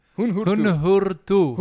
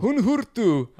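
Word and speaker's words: Huun-Huur-Tu: They're called Hunhurtu. You should check them out. They're wonderful. – I don't even know how to spell that. – H-U-U-N space H-U-U-R space T-U. – Hunhurtu. – Hunhurtu.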